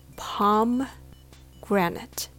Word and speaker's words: pomegranate: I got confused if it was pomegranate, pomegranate, pomegranate so my friend said, Why don't you just remember palm tree? Palm granite.